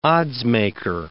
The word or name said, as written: oddsmaker